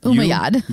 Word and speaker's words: Umayyad: Yeah, but I don't know Umayyad? – What a double M means. – Umayyad. – Umayyad. I want to pronounce both M's. – I don't think you should. Umayyad.